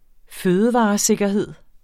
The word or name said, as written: fødevaresikkerhed